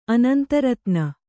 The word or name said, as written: ಅನಂತರತ್ನ